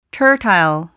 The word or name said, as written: tertile